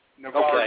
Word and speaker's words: Navarre: Navarre.